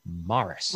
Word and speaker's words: Maurice: So men in England who have names spelled M-A-U-R-I-C-E pronounce it Morris.